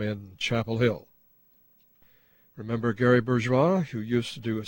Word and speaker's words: Bourgois: By the way, although he pronounced it Bourgeois, his name was spelled B-O-U-R-G-O-I-S.